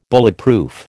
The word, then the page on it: Bulletproof: American English